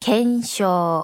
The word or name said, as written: けしょう